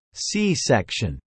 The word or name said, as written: C-section